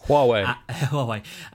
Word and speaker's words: Huawei: Huawei, and then another one which I forget.